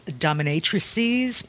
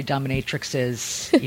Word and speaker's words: dominatrices: Technically, the plural of dominatrix is dominatrices, but nobody uses it correctly. So mostly people tend to say dominatrixes, even though it's supposed to be a C.